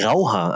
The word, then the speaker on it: Rauhaar: In, der Unterschied zwischen Grauhaar kurz. – Grauhaar. Ach, Rauhaar.